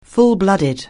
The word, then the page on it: full-blooded: anglais